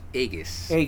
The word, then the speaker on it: Aegeus: And they take off, and so we get a small backstory on, I can't even pronounce it, Aegis, A-E-G-E-U-S? – Aegis. Yeah.